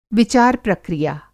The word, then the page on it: विचार-प्रक्रिया: Hindi